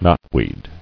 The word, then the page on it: Knotweed: knot·weed; Knot"weed"